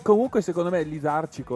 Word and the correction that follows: lisergico: lì. Lisergico, lisergico. Lisercico. Li- lisergico. Dai, Manco so pronunciarlo, Pro- ragazzi.